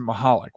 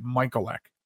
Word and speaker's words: Michalec: So I pronounce it Michalec. When I was a kid in Catholic school, we pronounced it Michalec.